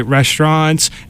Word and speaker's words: restaurants: There's a bunch of great restaurants and breweries. – What's that pronunciation of restaurants? – Restaurants. It's the French pronunciation.